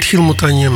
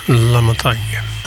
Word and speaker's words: La Montagne: Niet Gil Montagne, maar La Montagne. Waarschijnlijk moet je dat in het Engels uitspreken.